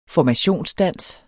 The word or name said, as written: formationsdans